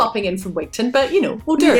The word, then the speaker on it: Wigtown: And I cannot wait to go to, is it Wigton? – Wigton. – I cannot wait to go to Wigton. – It's spelled Wigtown, but it's pronounced Wigton.